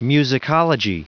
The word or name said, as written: musicology